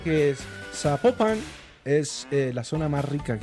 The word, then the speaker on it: Zapopan: Oiga, nos escriben, no, nos escriben los oyentes y que no, no se pronuncia Zapopan, sino que se pronuncia Zapopan.